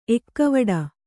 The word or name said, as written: ಎಕ್ಕವಡ